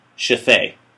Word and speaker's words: Shithead: I remember reading an article, this was a few years back, about a woman in the States who had named their kid Shafay. – What? – Shafay. – Shafay. – Shafay. – Shafay. – Now, you spell it S-H-I-T-H-E-A-D. – Shithead.